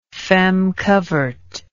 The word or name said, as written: feme covert